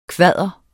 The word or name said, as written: kvader